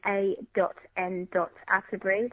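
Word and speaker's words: A.N. Attebery: My name is A.N.Atterbury. That is spelled A-T-T-E-B-E-R-Y.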